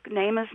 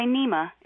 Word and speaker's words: Neema: And when people look at the cover of the book, Nema's name is spelled N-E-E-M-A. That double E in the Swahili language has an A sound. And so don't say Nema, it's Nema.